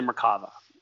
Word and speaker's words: Merkabah: but it's something called the Merkava. Or the Merkabah, depending on how you pronounce it.